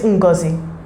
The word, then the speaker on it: Ngozi: So her name is Ngozi.